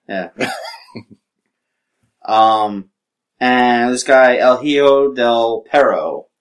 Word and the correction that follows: Hijo de Perro: Hijo de Perro. – There you go. Thank you. We're gonna go with that pronunciation.